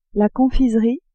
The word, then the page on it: confiserie: female